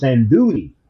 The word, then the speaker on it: andouille: He said, how did you say it? I said, andouille. And he said, no, it's andouille. And I said, oh.